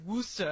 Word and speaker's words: Worcester: Although my favorite is in Massachusetts, starts with a W, and it's pronounced for real, and I don't get it, Worcester. – Yeah, Worcester. Yeah. – Worcester. – I lived in New York for a while. – But it's W-O-R-C-E-S-T-E-R.